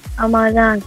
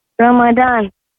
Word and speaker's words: Ramadan: Ramadan. – Sorry. Could you say that again? – Ramadan. – Ramadan. – Yes. – Could you spell that for me, please? – R-A-M-A-D-A-N.